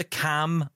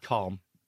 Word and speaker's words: Calm: the Cam app, and I said it right. Cam yeah. app. Calm.